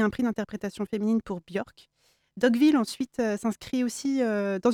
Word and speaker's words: Björk: la chanteuse Björk, si je prononce bien. – Oui, enfin j'espère.